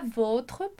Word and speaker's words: vôtre: E aqui tem um detalhe de pronúncia importante. A gente diz lá pro adjectif possessif, a gente vai dizer votre. E aqui vem o accent circonflexe para fazer com que a gente pronuncie votre. Então, olha lá a diferença. Votre, sem o acento, votre.